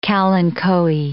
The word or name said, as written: kalanchoe